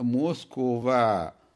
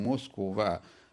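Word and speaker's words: موسكوفا (Москва): نعم احتفلت موسكو بمضي 800 سنة قبل سنتين احتفلت بمضي 800 سنة على إنشائها ويسمونها آه، موسكوفا هكذا ينطقون موسكو لا يقولون موسكو وإنما يقولون موسكوفا لكن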